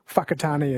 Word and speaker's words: Whakatane: I think the WH is pronounced F, like Whakatane is WH, Whanganui WH instead of F.